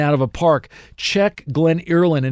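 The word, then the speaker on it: Glen Earlyn: It's G-L-E-N and then Early, E-A-R-L-Y, the letter N at the end. Glen Early and then N, Glen Irland is how it's pronounced.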